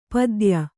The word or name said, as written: ಪತ್ಯ